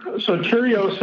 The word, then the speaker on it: Sotirios: Sotirios is more the formal name. Sotiris is sort of the short version.